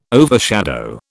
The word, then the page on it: overshadow: British English